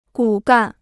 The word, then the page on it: 骨干: gǔ gàn